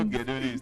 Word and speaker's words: Gedoni: You know Gedoni is 3 syllables.